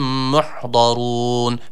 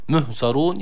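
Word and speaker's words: Muhdarun (مُحْضَرُونَ): Birincisi ha harfi, sakin olan ha harfine dikkat edelim. Bunu he ile okumayalım. Müh, muhzarun yanlış okuyuş olur.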